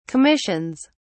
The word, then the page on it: commissions: British English